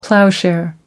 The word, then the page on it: ploughshare: American English